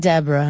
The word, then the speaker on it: Deborah: Deborah, not Deborah, honey. Okay, this is Whitley Gilbert Wayne's child. She was not named her no Deborah. Deborah, three syllables.